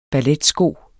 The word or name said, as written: balletsko